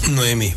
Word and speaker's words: Noemí: Luego Elimelech y ¿cómo se pronuncia? Naomi. – Noemí. – Noemí. Naomi es una señorita en nuestra iglesia. Sí. Me confundo siempre.